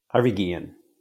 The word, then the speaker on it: Guillen: and Harvey Guillen. Did I say his name right? I think I said his name right. – Harvey Guillen. – Guillen. I came very close to not fucking that name up.